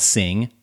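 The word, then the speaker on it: S!NG: The app of the day today is called Sing, but it's spelled S exclamation mark NG if you go searching for it and you're listening to this in the audio version.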